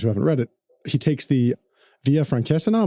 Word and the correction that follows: Francigena: Am I saying it right? – Francigena.